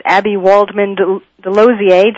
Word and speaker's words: Abbe Waldman DeLozier: My guest this half of the show is Abbe Waldman DeLozier.